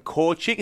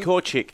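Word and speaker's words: Korczak: And he makes up that back row with Scott Higginbotham and Adam Korchik, who we saw come through the NRC with Brisbane City. He was good. Can you just go over that pronunciation again? – Korchik. Korchik. Yeah, 100%.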